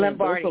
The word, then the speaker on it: Lombardi: Lamberti Trophy? Am I saying that wrong? I'm definitely Lombardi. Okay. Lam, Lombardi.